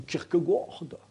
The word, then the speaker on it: Kierkegaard: Bah, c'est du Kierkegaard. C'est comme ça qu'on prononce en danois.